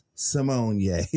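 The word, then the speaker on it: Symoné: Then RuPaul gonna say, "And that's how it's pronounced, Simone Ye." Then Raven gonna say, "It is Simone Ye."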